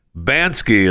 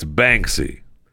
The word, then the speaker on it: Banksy: I keep calling it Bansky is not correct. It's Banksy. Okay. Bansky, right? What is it? It's Banksy. Banksy is how you say his name.